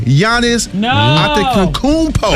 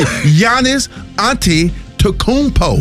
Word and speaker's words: Giannis Antetokounmpo: Did I say it right? – Did he say it? – Let me see. – Giannis Antetokounmpo. – There you go. – Come on now. – Yeah, you got it right, baby.